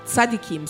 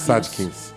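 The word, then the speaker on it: tzadikim: E lemos que os rabinos, né, quando chegaram por aqui, alguns passaram a ser vistos como, me corrija se eu pronunciar errado, tzadkins. – Tzadkims. – Tzadkims Sadikim, sábios. – Sadikins.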